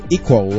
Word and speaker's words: EQO: 咁咧就系出咗一只叫做 EQO 嘅 software，咁就佢嘅读音就叫做 Equal 咁样啦，Equal 咁样咁嘅声呢个软件咧，佢嘅特点咧就系咧。